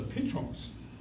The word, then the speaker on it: Petros: 事实上呢，原来希腊文的发音是 Simon，所以呢，我们中文翻的是照原来希腊文发音，所以翻成西门。西门是什么意思？就是听的意思。然后呢，彼得，彼得的话希腊文是用的 Petros。